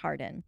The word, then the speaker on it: Hawarden: Eventually, Lucas admits to his real name being Thomas Harden, spelled H-A-W-A-R-D-E-N, but pronounced Harden.